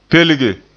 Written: pelge

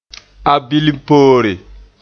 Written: abilimpoore

